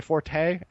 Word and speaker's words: forte: I'm doing algebra, which has never been my forte. Actually, I think it's pronounced Fort, but in the States, we love to like pretend like we know how to pronounce something in French.